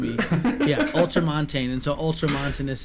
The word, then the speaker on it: ultramontanist: But I think that soon, that ultramontanist, did I say that right? Ultramontanist, yeah.